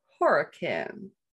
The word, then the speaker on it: Horicon: In 1987, the Tallman family moves into a new home in, it's spelled Horicon, but it seems to be pronounced Horicon, Wisconsin.